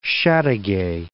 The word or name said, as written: Chateauguay